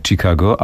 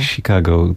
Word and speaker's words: Chicago: To Chicago dla nas oczywiście nigdy nie Chicago, ale mhm. Zaprezentowałaś właśnie tą wymowę, którą czasem słychać w westernach. Chicago, takie Chicago. Si jak tak. Chicago. – Tak, tak, u nas bardziej tak twardo, Chicago.